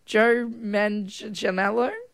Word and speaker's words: Joe Manganiello: And we've just had dinner, watching some TV, watching some Gordon Ramsay, and Gordon's got a guest on the show, Joe Manganiello.